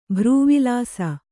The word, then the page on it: ಭ್ರೂವಿಲಾಸ: kannada